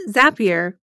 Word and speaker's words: Zapier: Pronounced Zapier, you know, like zapping data from one app to another, their company was designed to increase efficiency for business owners.